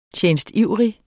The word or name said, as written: TJENSTIVRIGT